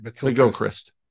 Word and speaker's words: McGilchrist: I think McGilchrist has, did I say it right? – McGilchrist. – I always add a Gila in there because I think that's every Irish name.